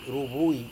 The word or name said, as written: ruvui